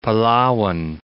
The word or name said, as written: Palawan